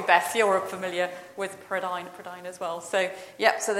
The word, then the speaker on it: Prydain: This is Welsh, so I had to check with Jeff the pronunciation of Prydain. Prydain is the Welsh word for Britain.